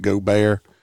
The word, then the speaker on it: Gobert: It's pronounced Gobert, but they're not from the area. So a lot of people mispronounce names and stuff down here.